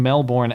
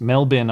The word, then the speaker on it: Melbourne: I was told before we went live that this meetup in Melbourne actually brought a tear, Melbourne, sorry, I mispronounced, Melbourne, Australia, brought a tear to your eye.